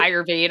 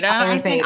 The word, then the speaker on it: Ayurveda: Ayurveda, and I'm probably uh, is that right? – Ayurveda. – Ayurveda. I am probably okay.